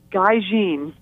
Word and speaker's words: Gaijin: It's Gaijin. – Gaijin. Okay, Gaijin. – Yeah, it's a Japanese word, so it's pronounced a little differently.